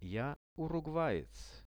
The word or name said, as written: ja urugvaec